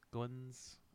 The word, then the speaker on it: Goins: Goins. Goins. I don't know. G-O-I-N-S. Goins, like coin.